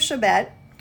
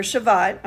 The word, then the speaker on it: Shevat: It says, in the 24th day of the 11th month, the month of Shabbat, or Shavat, okay? There's a lot of different ways you can pronounce it.